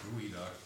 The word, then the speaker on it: geoduck: I know it looks like geoduck in print, but it's actually pronounced gooey duck.